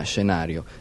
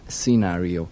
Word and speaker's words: Scenario: Da poco tempo, è uscito il suo nuovo LP che si chiama all'italiana Scenario e all'americana Scenario. Però a me mi sembra sempre più giusto, nonostante la sua americanità, pronunciarlo all'italiana, visto che le sue origini sono quelle.